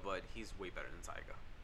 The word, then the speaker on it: Tyga: I'm glad he actually said it right, Tyga. – Yeah, Tyga. Tiger. Who mispronounces that? – I don't know. I mean, I knew people that would like mispronounce it. – Tigger. – I don't know. Tigger.